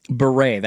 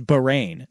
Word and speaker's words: Bahrain: Beret, that's how you say it. – Beret? – It's like B-U-R-E-I. – There is an I-N at the end. I think it is Bahrain is how it's pronounced. – Okay. – B-A-H-R-A-I-N.